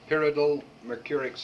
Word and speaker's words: pyridyl mercuric: Now, what about the process for, do I pronounce it right, pyridyl mercuric salt? – Yes, there are 3-4 patents in there on that subject.